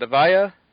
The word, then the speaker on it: Avaya: Avaya? – Mm-hmm.